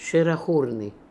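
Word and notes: Sherakhoorian: Russian

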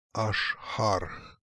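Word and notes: Ashhark: Russian

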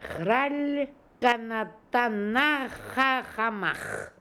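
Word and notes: khralkanatonschacher: Russian

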